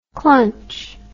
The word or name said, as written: clunch